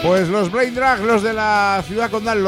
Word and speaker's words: Braindrag: presentamos a Brain Drag, lo digo bien.